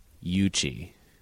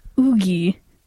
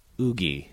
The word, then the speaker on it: Yuqi: Y-U-Q-I. – So my guess would be Yuchi. – Nope. – Okay. – Oogie. – Oogie. – Mm-hmm. – Wow. – It's so funny asking people how they think it's pronounced, and they just say something like Yuki or what you just said.